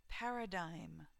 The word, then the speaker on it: paradigm: 2, paradigm. Paradigm. The pronunciation of this word is unusual.